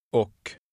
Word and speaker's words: och: Now say and. – Oh. Oh. – That's how the word is normally pronounced in a sentence. Here's how to say it when it's isolated or emphasized. Listen. – Och. Och.